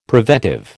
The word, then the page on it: preventive: American English